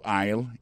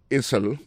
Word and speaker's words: aisle: Go to aisle 11 and you will find it. Aisle là, c'est A-I-S-L-E. Vous prononcez aisle. Il marque Essel.